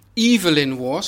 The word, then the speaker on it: Evelyn Waugh: Toen ging Evelyn Waugh, zo spreek je het uit.